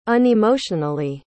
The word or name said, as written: unemotionally